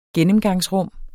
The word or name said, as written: gennemgangsrum